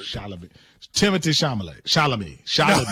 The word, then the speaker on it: Chalamet: it Timothy Chalamet? – He said Charlamagne.